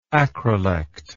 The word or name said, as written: acrolectal